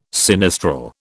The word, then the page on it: sinistral: British English